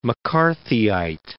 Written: McCarthyite

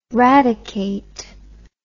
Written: radicate

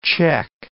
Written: Czech

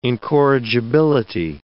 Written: incorrigibility

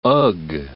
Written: ugh

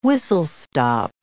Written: whistle-stop

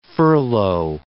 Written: furlough

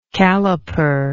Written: calliperer

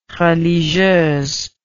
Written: religieuse

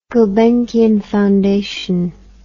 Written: gulbenkian foundation

